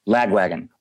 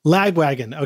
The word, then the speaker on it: Lagwagon: During his four-decade career, his collaborations and credits have included playing guitar in punk legends Lagwagon. Am I pronouncing that right? – Lagwagon. – Lagwagon.